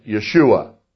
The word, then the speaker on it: Yeshua: Now, in the messianic circles of today, we abbreviate it, and it goes Y apostrophe S-H-U-A. Y apostrophe S-H-U-A, pronounced Yeshua.